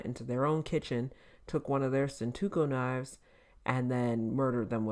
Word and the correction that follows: Santoku: How dare you disrespect the Santoku knife? – Oh, that's how you say it, Santoku?